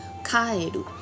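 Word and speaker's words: 帰る: 因为这里供奉的是平将门的首级，那平将门的首级顺利回到故乡卡埃鲁，就是日文里归来、顺利回来的意思。那卡埃鲁这个发音呢，也和日文中青蛙的发音相同。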